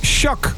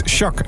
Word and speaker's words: SHQQ: Hoe spreek je dat nou uit, dat SHQQ? We waren er vorige week over eens. We waren het erover eens. Het zou Shaq moeten zijn. SHQQ, dat moet Shaq zijn.